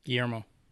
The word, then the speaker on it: Guillermo: Guillermo.